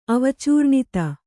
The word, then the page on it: ಅವಚೂರ್ಣಿತ: Kannada